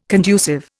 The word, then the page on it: conducive: Indian